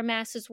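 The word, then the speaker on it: Maas: But now that I know her name's pronounced Mass, it's interesting that she has a shrine to her.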